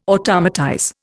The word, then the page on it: automatize: Indian